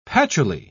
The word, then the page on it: patchouli: British English